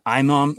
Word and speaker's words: imam: Apparently his and I don't know how to say this word, Conan, I-M-A-M. – Iman? – Like imam or imam. It's when you're like an Islamic leader. I-M-A-M. Right. Imam or whatever. – Yeah, so imam.